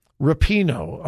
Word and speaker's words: Rapinoe: Rapinoe.